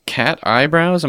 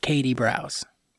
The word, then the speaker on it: Kat Eyebrows: As it's spelled, Kat Eyebrows. – Okay. – So that's how it's pronounced. And yeah, thank you so much. – So it's not Katie Brows? – No. Okay. It is exactly as it's spelled. It's just, you know, Kat Eyebrows.